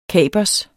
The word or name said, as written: kapers